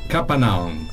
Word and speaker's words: Kappanoun: Io volevo capire esattamente come si pronuncia. – Allora, la pronuncia è Capanaon.